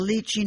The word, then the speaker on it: Lychee: Like from the lychee nuts that we used to get in Chinese restaurants? – That's right, lychee nuts.